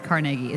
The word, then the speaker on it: Carnegie: Honestly, I know the the right pronunciation is Carnegie.